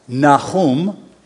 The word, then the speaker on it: Nahum: That's our second book, Nahum, if you want the Hebrew pronunciation.